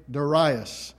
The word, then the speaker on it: Darius: And I know that some people pronounce it Darius and some people pronounce it Darius. And since I'm speaking on this subject, I might as well go ahead and tell you, I'm going to say Darius.